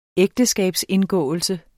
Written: ægteskabsindgåelse

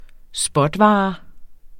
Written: spotvare

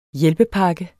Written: hjælpepakke

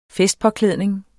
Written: festpåklædning

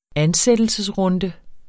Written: ansættelsesrunde

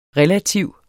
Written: relativ

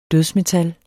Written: dødsmetal

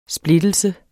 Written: splittelse

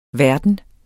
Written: verden

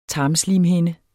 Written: tarmslimhinde

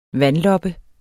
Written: vandloppe

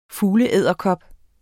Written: fugleedderkop

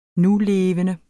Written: nulevende

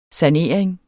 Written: SANERINGEN

